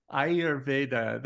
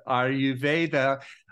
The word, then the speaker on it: Ayurveda: Yes, everyone better. Pronounces it different. It's Ayurveda. – Ayurveda, that is great. Well, I'm so looking forward to hearing about this today and diving deep with Ayurveda.